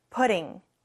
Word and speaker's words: putting: 뒤에 ing가 붙으면서 putting으로 발음이 바뀝니다. 조금 T 발음이 부드러워져요. putting 같이 해볼까요?